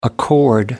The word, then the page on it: accord: American English